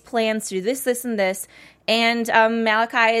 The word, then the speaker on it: Malachi: Malachi.